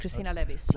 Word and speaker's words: Cristina Levis: Christina Levis.